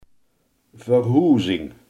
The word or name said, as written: verhuizing